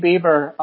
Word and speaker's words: Bieber: Shane Bieber yes.